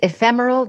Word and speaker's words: ephemeral: This love of words, how did I do on pronunciation? – Oh, perfect. Perfect. Ephemeral, diaphanous, and squat, and obstreperous.